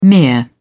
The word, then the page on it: mere: British English